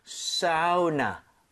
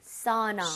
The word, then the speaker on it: sauna: 근데 이거를 진짜 사우나. 이렇게 아우 이렇게 하는 사람도 있습니다. 근데 일반적으로 전 추천하는 것이 AU 혹은 AW라는 스펠링이 보이잖아요. – 예. – 그러면 아우보다는 어 이런 느낌. – 사우나.